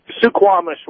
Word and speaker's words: Suquamish: Squamish. Please pronounce it, Washington. – Suquamish, Washington. – Suquamish, thank you.